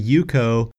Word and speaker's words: UCO: So our low-tech alternative to the Mountain Glow tents is the Yuko Micro Candle Lantern. And by the way, Yuko is spelled U-C-O.